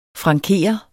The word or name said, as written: franker